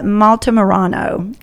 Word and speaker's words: Altamirano: And so today we are especially blessed to have with us on the podcast, Angela Altamirano.